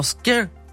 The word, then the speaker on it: Skyr: Und kommt aus Island, wird deswegen auch Skyr ausgesprochen.